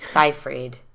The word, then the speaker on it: Seyfried: Safray. – Amanda Safray. I don't know if that's saying her name right. – Seyfried.